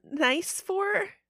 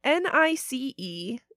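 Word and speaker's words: Nicéphore: It's not pronounced nice, as in very nice. – Right. – The French way of saying it sounds like nice for.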